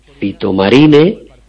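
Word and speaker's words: Phytomarine: Por un lado, uno que es un quemagrasas, que se llama Drenacel Fitomarine.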